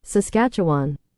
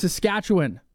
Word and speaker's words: Saskatchewan: Saskatchewan. – Saskatchewan. It's Saskatchewan.